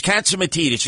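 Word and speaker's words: Catsimatidis: If John Katsimatidis doesn't know, and that's the other thing, why do Greeks have such long last names? It's Katsimatidis. It took me like 2-3 years to pronounce it correctly.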